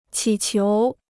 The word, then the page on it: 乞求: qǐ qiú